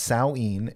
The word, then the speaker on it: Samhain: But it's pronounced Samhain.